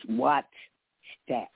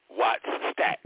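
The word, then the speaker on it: Wattstax: You keep saying the name wrong, darling. It's Wattstack. – Right, correct. Wattstack.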